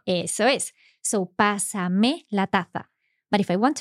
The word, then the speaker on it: pásamela: So that's to maintain the stress on that first syllable. Pásamela. – Eso es. Yes. – Because you look at that and you see P-A-S-A-M-E-L-A.